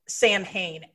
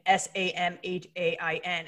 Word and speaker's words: Samhain: And it's spelled Samhain, Samhain. And